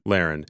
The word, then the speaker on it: Lahren: Tommy Lahren? – Lahren.